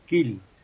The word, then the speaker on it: Kiel: Por ejemplo, la IE, IE hmm. en alemán es I. Por ejemplo, Kiel, es la ciudad portuaria, es Kiel.